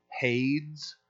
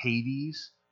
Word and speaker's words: Hades: You'll find the old poets that refer to Sheol, and then you'll also find the term Hades, and or it's sometimes pronounced Hades.